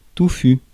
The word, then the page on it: dim: /dɪm/